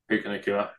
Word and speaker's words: Puka Nacua: Oh, I've already mentioned Nakua. Is that how you say it? – Puka Nacua, yeah.